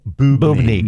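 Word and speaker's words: boobne: And lastly, I had, so this word, B-O-O-B-N-E, I'm not sure how you pronounce it, boobney, I don't know.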